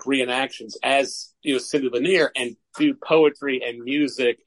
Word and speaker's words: Lanier: We say Lanier, so I don't know if that's. But that's how it's said around here.